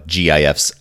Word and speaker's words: GIF: Yeah, so, you know, that webpage was just a page of a bunch of animated GIFs. That's why I pronounce it GIF, everybody, because I'm going G.